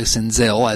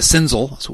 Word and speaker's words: Senzel: And the first thing is we've learned to pronounce his name correctly, Senzel, as in Denzel Washington, instead of Senzel, which we all called him originally.